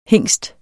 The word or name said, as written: HINGSTENS